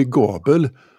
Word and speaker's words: Gabel: En, en, på tredje plats i alla fall, Christian Gabel, jag antar att det uttalas så, eh, Krater. – Han är ju från Karlstad, så då blir det Gabel.